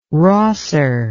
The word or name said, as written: rosser